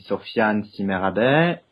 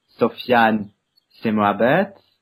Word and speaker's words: Sofiane Si Merabet: It depends actually on which language you are going to pronounce it. If it's in French, it will be Sofiane C. Merabet. In English, Sofiane C. Merabet.